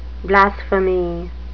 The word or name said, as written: blasphemy